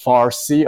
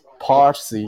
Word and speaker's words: Parsi: Uh, Arabs don't have the uh, the P. So instead of pe, they say fe. – So uh, that's why our, our language is now pronounced Farsi, although it's Parsi.